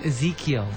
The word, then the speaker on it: Ezekiel: Ezekiel? – Mm-hmm. Ezekiel.